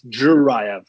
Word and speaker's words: Zhirayev: Just like a slight more D sound at the beginning of that. It's like a D going. Yeah, a little J heavy, but the J is still there. Just following me. So it's Zhirayev.